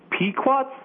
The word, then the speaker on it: Pequot: Pequots.